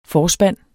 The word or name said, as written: FORSPANDENE